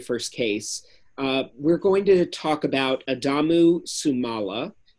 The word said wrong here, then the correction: Sumaila: Did I say that right, Art? – I've been saying Sumaila. It's Sumaila.